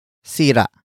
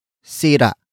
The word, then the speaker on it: sira: And that is sira. Sira. Sira. Sira is spelled as S-I-R-A. Note the glottal stop at the end.